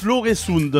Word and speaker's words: Øresund: Nous on prononce l'Øresund.